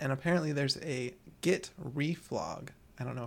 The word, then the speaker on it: reflog: So reflog, yeah. – Reflog, okay. Yeah. – I'm looking at your notes. I heard you, and I'm trying to process that in my mind. Yeah, reflog.